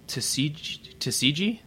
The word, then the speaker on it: Tsukiji: I believe the brand is called Sugimoto, and it was made in that in the neighborhood Tsukiji. That's how you pronounce it.